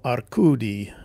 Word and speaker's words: Arcudi: and the Bruno Arcudi Literature Prize.